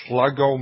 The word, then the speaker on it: Sluggo: And it's spelled just like it sounds. S-L-U-G S-L-U-G-G-O, Slug Go.